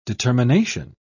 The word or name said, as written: determination